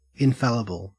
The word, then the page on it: infallible: Australian English